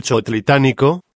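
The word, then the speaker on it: Chootlitánico: A fin de podáis anotar y tal palabra, la repetiré letra por letra, C, H, O, O, T, L, I, T, A con acento agudo, con acento en la, A, N, I, C, O pero, ¿En qué consistió la época catastrófica Dios del Chotlitánico?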